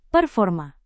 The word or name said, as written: performa